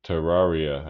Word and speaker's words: Terraria: That was when we were fighting the aliens on Terraricon. – I agree. – Yeah. – Yeah, Terraria. I think you said it wrong.